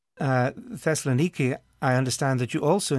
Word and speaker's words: Thessaloniki: In Thessaloniki, this is how we pronounce it today, Thessaloniki.